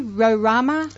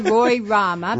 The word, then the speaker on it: Rorama: Cause I used to say Roy Rama, I think it's Rorama.